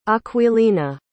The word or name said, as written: Aquilina